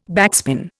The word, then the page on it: backspin: Indian